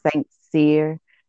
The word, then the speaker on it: Saint Cyr: It's Saint Cyr, and they usually say Saint Cry or something like that.